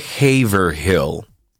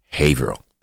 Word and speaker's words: Haverhill: además de Boston y no se dice Haverhill. ¿Ok? Allá se dice Haverhill.